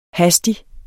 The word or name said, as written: hastig